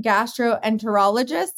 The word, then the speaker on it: gastroenterologist: You're a practicing gastroenterologist.